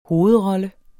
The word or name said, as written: hovedroller